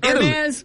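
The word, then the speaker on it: Hermès: Savannah James was on a podcast the other day, and she was speaking about her experience at a Hermes store in Hermes. – Italy. Is that how you see? I always hit the H. – The H is silent.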